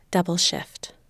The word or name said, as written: double shift